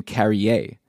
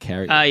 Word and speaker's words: Carrier: It's William Carrier, not Carrier.